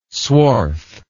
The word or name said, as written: swarth